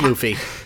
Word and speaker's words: Luffy: Yeah, it's pronounced Luffy.